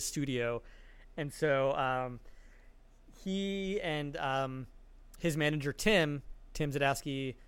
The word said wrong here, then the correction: Zahodsky: That's Tim Zahodsky. – Oh, is it Zahodsky? Okay, I never knew how to say it. – Yeah, Zahodsky.